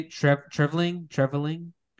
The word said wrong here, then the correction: Treliving: Kirby? – Yeah, Brad Treliving. – Treliving.